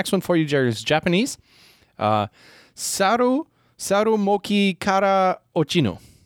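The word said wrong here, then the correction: Ochiru (落ちる): I think that's how you say it. Ochiru. Sorry. So it's saru moki kara ochiru.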